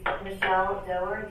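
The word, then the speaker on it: Michelle Dougher: That is Michelle Doerr, D-O-U-G-H-E-R.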